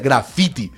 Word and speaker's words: graffiti: En Colombia nosotros lo que hicimos fue reivindicar también la epistemología y decir no, porque de hecho la pronunciación no es graffiti, la pronunciación nuestra sería graffiti y sería con esa T que tiene fuerza.